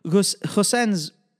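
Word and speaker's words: Gosens: I mean, Goosens, as I'm told his name is pronounced, he pretty much like tapered off after 60 minutes.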